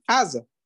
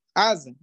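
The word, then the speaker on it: Asa (עזה): Em hebraico, como se fala Gaza? – Gaza. – Asa. Asa. E Asa aparece aqui no Tanakh.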